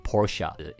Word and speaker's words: Porsche: 除了 Mercedes 之外呢，还有另外一个常常有争论的念法的，叫做 Porsche。因为它是德国车嘛，所以如果要以德文念法呢，当然是要念 Porsche。